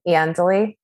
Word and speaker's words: Iandoli: Hi, I'm Kathy Iandoli, also known as Kath 3000.